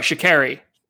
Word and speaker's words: Sha'Carri: And sorry, Sha'Carri. It's pronounced Sha'Carri. I say Kari because I have the accent, whatever, but I get on people about that.